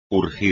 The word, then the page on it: urgir: espagnol